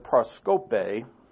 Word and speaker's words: proskope: Offense is the Greek word proskope. See, the little accent is on the last vowel. That's what you emphasize.